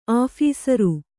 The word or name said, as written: ಆಫೀಸರು